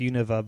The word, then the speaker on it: Unova: Well, mine would be Unova, even though I think it's pronounced Unova, but it's stupid.